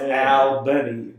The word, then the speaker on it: Albany: Well, if you look at the how it's spelled, it is one L. So it's Albany.